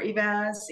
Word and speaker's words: Eivaz: Again, Jennifer Eivaz, you did say it right.